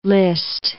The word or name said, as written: Liszt